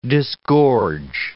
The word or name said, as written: disgorge